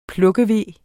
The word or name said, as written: plukkeve